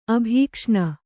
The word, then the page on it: ಅಭೀಕ್ಷ್ಣ: abhīkṣṇa